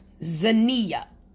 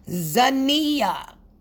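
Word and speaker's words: Xania: So I just wanted to let them and anyone else know who's trying to pronounce my name, it is pronounced Zania. Okay? Let me say it again. Zania. Okay? So they see the X, and I don't know. The X just throws them off, and they say everything but what it is.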